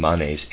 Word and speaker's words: Manes: I am pronouncing Manes M-A-N-E-S, and Manichaean is M-A-N-I-C-H-E-A-N, Manes. When we speak of the great leaders of mankind, we must also think of this individuality who has set himself this task.